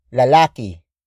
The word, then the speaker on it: lalaki: And that is lalaki. Lalaki. Lalaki. Lalaki is spelled as L-A-L-A-K-I.